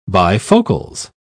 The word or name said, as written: bifocals